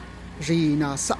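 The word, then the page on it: shina'za: Teotitlán del Valle Zapotec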